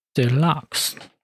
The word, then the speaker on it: Deluxe: Deluxe Deluxe 重音在后。